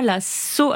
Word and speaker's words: Saulx: Patrice nous écrit euh, pour la troisième fois la Sceau, Alors je crois qu'on dit la Sceau, ça s'écrit S-A-U-L-X,